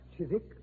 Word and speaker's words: Chiswick: Chiswick? It was originally pronounced Chiswick, Watson, and it's still so spelled.